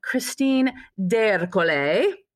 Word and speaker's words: Christine D'Ercole: I am sitting across the Zoom squadcast screen here from Christine D'Ercole.